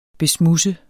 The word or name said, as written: besmudse